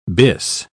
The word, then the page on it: bis: British English